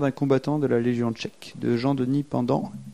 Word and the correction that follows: Pendanx: et Chris. – Pendanx. – Pendanx.